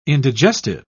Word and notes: indigestive: British English

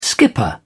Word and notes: skipper: British English

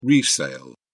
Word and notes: resale: British English

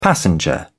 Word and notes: passenger: British English

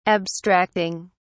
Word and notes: abstracting: British English

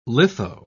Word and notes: litho: British English

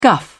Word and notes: guff: British English

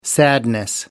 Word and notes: sadness: British English

